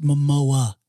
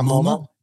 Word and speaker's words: Momoa: So let's just say a guy like Jason, by the way, it's Momoa. It's not the Museum of Modern Art. It's not MoMA.